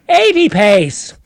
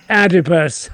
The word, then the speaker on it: Antipas: You know, in academia, we refer to him as Antipace. But if it makes you more comfortable, I'll use the more inexpert pronunciation, Antipas.